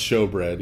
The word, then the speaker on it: showbread: Okay, so, this is the table of the showbread, spelled like shoebread in the King James, but still pronounced showbread, even when it's spelled like that, for the record.